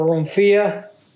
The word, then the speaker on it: Romphaia: R-O-M-P-H-A-I-A. Romphaia or Romphia, however you choose to pronounce it, it's said both ways.